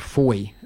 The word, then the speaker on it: Fowey: And one of the highlights, one of the best meals, was we're in a place called Foy. It's spelt F-O-W-E-Y.